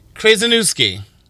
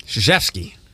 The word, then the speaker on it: Krzyzewski: Krasinowski. – Krzyzewski.